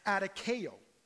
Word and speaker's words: adikeo: And you pronounce it as adikeo.